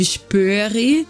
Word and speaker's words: Spoerri: Quem tiver interesse, coloca aí no YouTube Spurri. É, se escreve S-P-O-E-R-R-I, que vocês vão encontrar aí as esculturas, tá? – E além de muita arte, tarô, né?